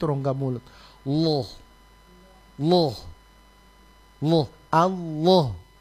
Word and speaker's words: Allah: Allah itu dia ditebalkan fakhimil lama, lam pada kata Allah itu lam di tengah, Allah itu ditebalkan. Jadi bacanya kalau minimal orang baca lo lo Allah. Allah, coba bilang Allah.